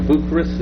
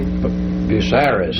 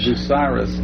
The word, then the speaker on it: Bucyrus: Bucharest, is that how you... – Bucyrus. – Bucyrus. – Bucky Russ, some people say.